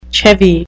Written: chevy

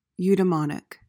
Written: eudemonic

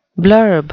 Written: blurb

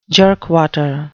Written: jerkwater

